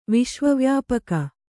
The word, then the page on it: ವಿಶ್ವವ್ಯಾಪಕ: viśva vyāpaka